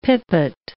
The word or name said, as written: pipit